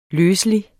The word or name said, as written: løselig